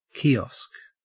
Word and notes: kiosk: Australian English

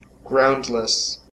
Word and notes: groundless: American English

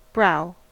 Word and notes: brow: American English

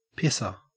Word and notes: pisser: Australian English

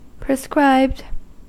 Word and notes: prescribed: American English

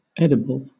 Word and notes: edible: British English

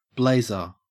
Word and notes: blazer: Australian English

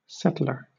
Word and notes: settler: British English

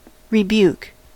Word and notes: rebuke: American English

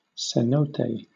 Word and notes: cenote: British English

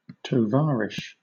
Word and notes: tovarish: British English

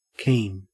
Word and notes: keen: Australian English

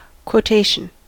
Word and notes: quotation: American English

